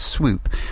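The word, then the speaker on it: Swope: The band was formed by Roger Miller on guitar, Clint Clonery on bass, Peter Prescott on drums, and Martin, I pronounce this as Swoop, on tape manipulation sound engineering.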